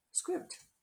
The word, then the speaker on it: Scribd: How do you pronounce Scribd? Oh, I'm saying it right. Scribd sounds like ribbed, but with an SC in front.